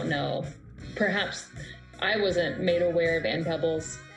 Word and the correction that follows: Peebles: I hope I'm saying her name correctly. Pebbles, correct? Yeah. Not Peebles. – But it's double E, so maybe Peebles. It is Ann Peebles.